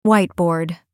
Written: whiteboard

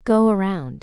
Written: go around

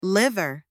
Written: liver

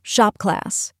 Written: shop class

